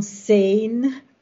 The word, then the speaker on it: Sein: O da em alemão, a palavra da Zain, para quem não sabe, se escreve em português, a gente fala da tracinho sein S-E-I-N, em alemão isso se pronuncia Sein.